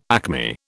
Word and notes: acme: British English

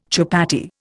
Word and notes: chupatty: Indian

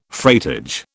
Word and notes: freightage: British English

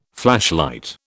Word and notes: flashlight: British English

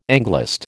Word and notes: Anglist: American English

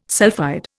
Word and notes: sulphide: Indian

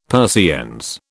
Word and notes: Persiennes: British English

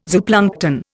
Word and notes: zooplankton: Indian

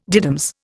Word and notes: diddums: Indian

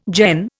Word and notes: Jain: Indian

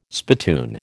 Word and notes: spittoon: American English

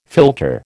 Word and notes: philtre: American English

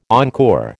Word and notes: encore: American English

